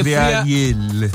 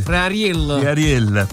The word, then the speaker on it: friarielli: Pronuncialo bene, salsicce e friarielli. – Friarielli.